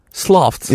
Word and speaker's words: sloths: How do you say Sloths. – Sloths. Yeah, that, okay. that th. Yeah, ths.